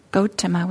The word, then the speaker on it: Gotama: Gotama is the, if we want to be technical about Pali, was how we would pronounce it, but Gotama, I think, is how a lot of us in English pronounce it.